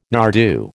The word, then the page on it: nardoo: American English